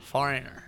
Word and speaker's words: Foreigner: ¿Cómo se pronuncia, señor? – Foreigner. – ¡Oh, no! ¡Foreigner! ¡Qué bonito habla usted, señor!